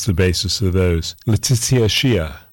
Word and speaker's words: Xiuh: Her last name is spelled X-I-U-H, but I believe it's pronounced Shia. Letizia Shia from Cusco in Peru.